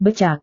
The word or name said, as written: becak